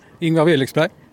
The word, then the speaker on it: Ingvar Willexberg: Ingvar Willexberg. – Willexberg, det är det här med betoning som jag märkt är väldigt annorlunda här i Kävlingetrakten än vad jag är van vid.